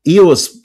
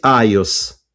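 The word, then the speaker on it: iOS: Иос, надо произносить айос.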